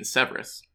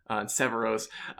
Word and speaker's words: Severos: I've been saying Severus because we were like, that's how you say it in Severus. And I think, unfortunately, I'm just going to say it correctly. I'm consciously trying to have NPCs that aren't Severosi say Severos.